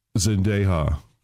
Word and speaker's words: Zendaya: Zendaya.